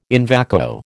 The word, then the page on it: in vacuo: American English